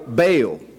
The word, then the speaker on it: Baal: He and Mephibosheth have similar names in that the second part of their name has something to do with the idol Baal, B-A-A-L, or Baal, as some of the Hebrew scholars would pronounce it.